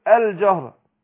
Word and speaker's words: الجهر: في نقطة هنا برضو بيقع فيها البعض اما اقول الجهر هنا اللام قمرية اقول اذا ما اقول القمر لكن في البعض بينطقها زي اللام الايه الشمسية اقول ايه الجهر لا اقول الجهر يبقى انا اظهر هنا الايه اللام.